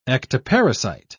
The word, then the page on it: ectoparasite: British English